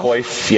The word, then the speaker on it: Coif: was elected to the Order of how do you say, Coif? – Coif, yeah.